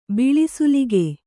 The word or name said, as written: ಬಿಳಿಸೂಲಿಗೆ